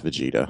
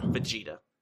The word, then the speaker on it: Vegeta: It is pronounced Vegeta. – Vegeta.